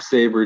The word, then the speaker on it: Sabre: How do you pronounce this guy's last name? – Zack Sabre Jr., yeah.